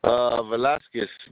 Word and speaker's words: Velasquez: Velasquez. V-E-L-A-S-Q-U-E-Z. – Okay. That's Carlos, C-A-R-L-O-S. And your last name is spelled D-E-L-A-S-T-U-E-Z. – S-Q-U-E-Z.